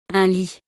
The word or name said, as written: Un lit